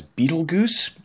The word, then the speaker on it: Betelgeuse: Number four, I kept pronouncing the name of a star as Betelgeuse, when in fact it sounds the exact same as the movie Betelgeuse.